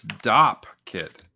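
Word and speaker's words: dopp kit: It's Dop kit. D-O-P-P kit.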